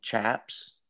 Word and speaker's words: chaps: So, since we're talking about shaps now, and I'm good to pronounce it wrong because I have a Canadian friend that listens to the show, and during Tyler's episode, him and I just kept calling them chaps.